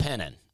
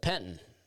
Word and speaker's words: Penton: Now, if you're from Penton, P-E-N-T-O-N, it's called Penton. But I, you know, and I am from there. My family's from there, but I grew up in Opelika. And I always just kind of pronounce it Penton, but it's Penton.